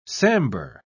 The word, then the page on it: sambur: British English